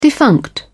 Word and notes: defunct: British English

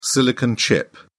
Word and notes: silicon chip: British English

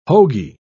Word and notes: hoagy: British English

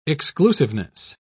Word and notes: exclusiveness: British English

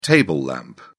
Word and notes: table lamp: British English

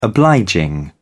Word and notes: obliging: British English

